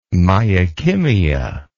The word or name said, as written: myokymia